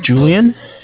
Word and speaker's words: Julian: Okay, my name's Julian. It's J-U-L-I-A-N.